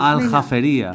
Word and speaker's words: Aljafería: Aljafería.